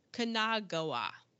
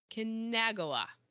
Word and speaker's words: Kanagawa: And Hiro Kanagawa. Man, I butchered that. Hiro Kanagawa as the pet shop owner.